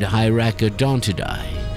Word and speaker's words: Hyracodontidae: So the nominative plural or genitive singular of any noun that ends in A-E, a first declension Latin noun, should be pronounced I. And that's why I say Paraceratheri and Hyracodontidae.